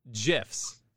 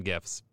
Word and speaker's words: GIF: We're also going to do, and I'm going to say this wrong to about half the people out there, GIFs. They're called GIFs. That's what it is. GIFs. It's a GIF or it's a GIF for you weird people out there.